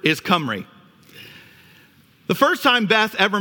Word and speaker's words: Cymru: Now, croeso means welcome to, and then the way you pronounce that word, G-Y-M-R-U, is Cymru, like a C or a K. All right, so I want you to say that after me. Cymru.